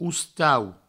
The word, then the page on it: ostau: oustau